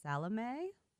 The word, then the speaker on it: Salome: I have a Sylvia Moreno Garcia that I picked up, The Seventh Veil of Salome. Would that be right? – That's the correct pronunciation.